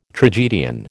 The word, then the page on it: tragedian: American English